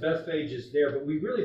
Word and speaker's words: Bethphage: I call it Bethphage. Okay, there's different pronunciation.